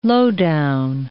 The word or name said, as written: lowdown